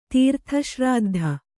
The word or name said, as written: ತೀರ್ಥಶ್ರಾದ್ಧ